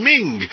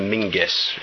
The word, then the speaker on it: Menzies: his name's spelt Menzies, quite frankly, and we call him Ming.